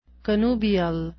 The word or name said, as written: connubial